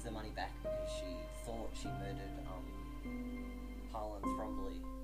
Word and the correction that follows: Thrombey: I can't say it right. – Thrombey. – There's no L. I think there's an L, though. – No, there isn't.